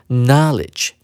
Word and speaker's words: knowledge: 我们注意到动词念成 know，而名词呢就不念 know 了，它要念成 knowledge。